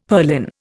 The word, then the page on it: purlin: Indian